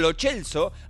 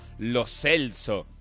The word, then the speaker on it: Lo Celso: Al parecer le venimos diciendo lo chelso hace un montón de años. Y nadie le preguntó cómo se pronuncia. Y al parecer ayer hubo un video donde él arranca y dice, no, lo celso.